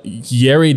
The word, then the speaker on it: Yeri: Yeah, but another thing that excited me was that Yeri De Los Santos, sorry, I had to make sure that I say that right. – Like dairy, but rhymes with, yeah.